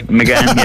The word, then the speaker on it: Magan: How do I do in my pronunciation of Moncon Magan? – Yeah. You're good. McGann, yeah, all good.